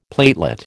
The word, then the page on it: platelet: American English